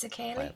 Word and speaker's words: Sukeile: It's Sukeile.